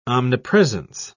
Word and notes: omnipresence: British English

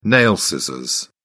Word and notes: nail scissors: British English

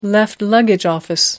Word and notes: left luggage office: American English

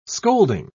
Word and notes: scolding: British English

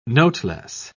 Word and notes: noteless: British English

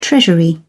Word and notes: treasury: British English

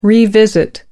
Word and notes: revisit: American English

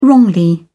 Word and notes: wrongly: British English

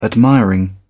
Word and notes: admiring: British English

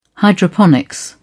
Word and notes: hydroponics: British English

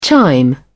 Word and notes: chime in: British English

